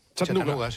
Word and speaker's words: Chattanooga: ¿Se pronuncia así en Chattanooga? – Chattanooga. Sí.